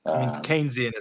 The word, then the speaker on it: Keynesian: Like it disincentivizes productivity versus the, what is this, Keynesian thought processes? Is that right? – Keynesian is how you pronounce it, but that tends to be whatever, Keynesian.